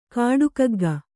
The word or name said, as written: ಕಾಡುಕಗ್ಗ